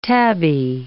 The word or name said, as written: tabby